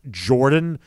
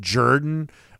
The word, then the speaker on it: Jordan: But nonetheless, this has been a thing in the South for a while, and Kirby Smart's son there to remind you that it's pronounced Jordan and not Jordan.